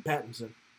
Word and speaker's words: Pattinson: So I think, but this one, Robert Patterson, whatever. How do you say his last name? – Pattinson. – Pattinson? – Yes.